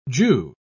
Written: jew

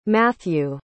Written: mathew

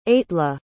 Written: aitla

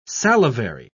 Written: salivary

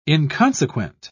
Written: inconsequent

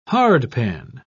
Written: hardpan

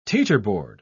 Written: teeterboard

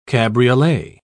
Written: cabriolet

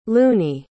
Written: loonie

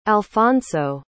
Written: alphonso

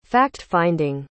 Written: fact-finding

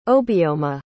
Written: Obioma

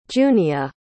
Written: junia

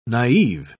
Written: naif

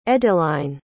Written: edeline

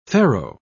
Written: farrow

